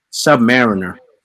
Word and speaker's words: Submariner: I mean, you said his name wrong, but... – How do you say it? Yeah, Submariner. – Oh, Submariner. Okay, my bad.